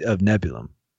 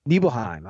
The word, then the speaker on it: Nibelheim: Nibelheim.